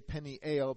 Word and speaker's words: Peniel: I say Peniel because that's the pronunciation in Hebrew.